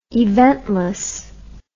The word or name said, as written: eventlessly